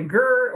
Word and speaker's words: GERD: But when you put the D at the end and you pronounce it like GERD, that's gastroesophageal reflux disease.